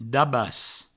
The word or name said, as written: dabas